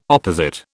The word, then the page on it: opposite: British English